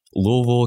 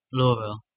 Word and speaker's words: Louisville: Louisville, Kentucky. – All right. – Right. That's the right way to say it. – Louisville.